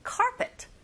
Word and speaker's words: carpet: 카페트가 아니라 여러분, carpet.